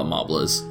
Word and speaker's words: Mobliz: It's Mobliz. Yeah. So it looks like Mobliz. So we'll just call it Mobliz.